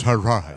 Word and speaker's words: Hadron: Is it Hadron or Hadron? – It's pronounced Hadron.